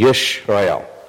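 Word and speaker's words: Yishrael (ישראל): This is a silent letter, so you don't pronounce this, and this colon turned on its side is basically an E. So you see Yishrael, R-A, Yishrael, okay? So that's the Hebrew word for Israel.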